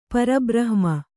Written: ಪರಬ್ರಹ್ಮ

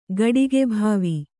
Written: ಗಡಿಗೆಭಾವಿ

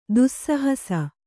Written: ದೋಸ್ಸಾಹಸ

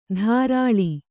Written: ಧಾರಾಳಿ

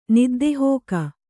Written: ನಿದ್ದೆಹೋಗು

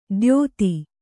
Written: ದ್ಯೋತಿ

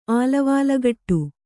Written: ಆಲವಾಲಗಟ್ಟು

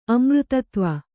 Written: ಅಮೃತತ್ವ

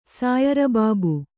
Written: ಸಾಯರಬಾಬು

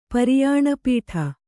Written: ಪರಿಯಾಣಪೀಠ

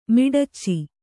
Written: ಮಿಡಚಿ

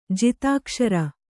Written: ಜಿತಾಕ್ಷರ

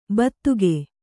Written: ಬೂತಿಕ್ಕು

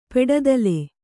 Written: ಪೆಡದಲೆ

